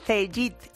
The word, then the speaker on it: Cegid: Pues claro que sí, es Cegid, aunque se escribe Cegid con G, y va a mejorar el día a día de los autónomos, asesorías, pymes y grandes empresas.